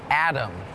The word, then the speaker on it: atom: Atom is tricky because it sounds like there's a D in it Atom but it's actually A-T-O-M but it's still pronounced Atom